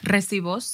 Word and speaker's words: recibos: Hi, guys, and welcome to another episode of your Recitos. Is that how you say it? Recibos.